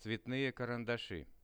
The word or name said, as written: cvetnye karandashi